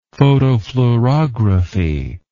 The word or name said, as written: photofluorography